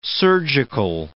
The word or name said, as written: surgical